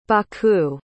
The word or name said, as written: baku